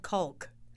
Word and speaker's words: caulk: There's an L in there that I caulking. Will pronounce. – Yes. – Let's talk about caulk.